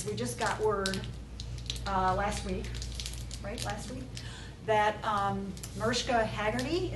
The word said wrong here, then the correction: Hargitay: is that how you pronounce it? She's from one of Hargitay.